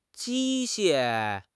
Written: 机械

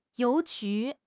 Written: you2 ju2

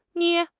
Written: 捏

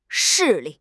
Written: 势力

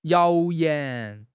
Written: 谣言

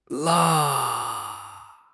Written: 啦

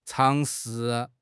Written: 尝试

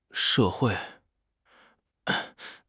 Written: she4 hui4

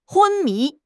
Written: 昏迷

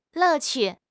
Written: 乐趣

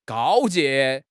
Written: 告诫

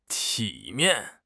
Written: 体面

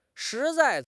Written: shi2 zai4

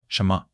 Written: 什么

